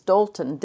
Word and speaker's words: Dalton: And I know since we've been traveling, you know, ours is Dalton, D-A-L-T-O-N, and mm-hmm. it's like tall and ball and call, and I spell it for people, and then they go, oh, Dalton.